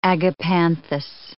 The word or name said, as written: agapanthus